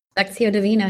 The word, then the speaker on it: Lectio Divina: I think it's called Lectio Divina. Did I say that right? – Lectio Divina, yeah.